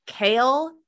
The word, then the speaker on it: Chaol: I don't know how you're supposed to pronounce his name. – I thought it was Kale. – Is it Kale? Chunk. It's spelled C-H-A-O-L. Give me a break with that pronunciation.